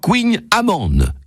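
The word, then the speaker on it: Kouign-amann: Le Queen Amann, spécialité breton, qu'on prononce en breton d'ailleurs Queen Amann, et dont la traduction révèle tout simplement la recette.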